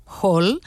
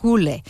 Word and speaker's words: Hole: Si vosaltres, com jo, sempre l'heu anomenat Hall, sapigueu que en noruec i en danès es pronuncia Hole, i la sèrie, que és danesa, es titula Detective Hole.